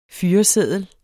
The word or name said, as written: FYRESEDLEN